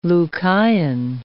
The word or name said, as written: Lucayan